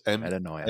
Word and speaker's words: Metanoia: Oh, and the name of his book, by the way, is it pronounced Metanoia? It's one of those things I've never pronounced. – Correct. – Yes, it's Metanoia.